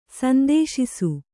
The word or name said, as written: ಸಂತೋಷಿಸು